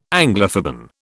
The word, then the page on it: Anglophoben: British English